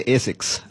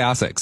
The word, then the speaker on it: ASICS: How do you pronounce the brand? ASICS. – In Asia, we say ASICS. – I would say ASICS. So there we go.